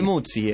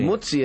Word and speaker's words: Muzzi: Es Ricky Musi, ¿no? – Porque le van dando como un style. – Musi. – Ricky Muzzi es. – Ricky Muzzi. El sí. Muzzi es como pizza.